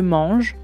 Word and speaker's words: mange: Por ejemplo, si digo la palabra je mange, yo como, je mange. Al final de mange hay una E escrita, pero es muda.